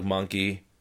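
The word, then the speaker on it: Mahnke: And then the main Superman book, Pete Tomasi and Patrick Gleason co-writing with Patrick Gleason and Doug Monkey. Mankey, I never know how to say his name. Monk. – I think it's Monkey.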